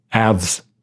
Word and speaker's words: Alves: And Jaws 3D, directed by Joe Alves? – So. Yeah, something like that. – Or Alves. Like, rhymes with halves? – Yeah.